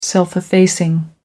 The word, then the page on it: self effacing: American English